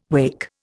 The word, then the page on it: wacke: Indian